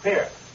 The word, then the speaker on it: Pierre: It's pronounced Pierre. – Pierre, South Dakota. – Pierre, South Dakota. We don't give it the French Pierre, no.